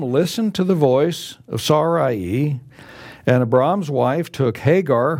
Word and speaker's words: Sarai: And now his wife Sarai, as we would say in English, but in Hebrew it's Sarai, the way it's pronounced.